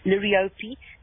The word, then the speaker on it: liriope: And the lily turf, the liriope, or some people pronounce it liriope, that has very nice lime green foliage and then also deep mauve flowers, and they can also handle quite deep shade.